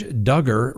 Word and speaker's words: Duggar: The Josh Duggar, rhymes with hugger, trial is getting ready to begin.